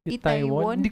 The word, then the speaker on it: Itaewon: Sana panoorin niyo rin Itaewon Class and Itai... How do you pronounce it? – Itaewon? – Itaewon Class and Startup.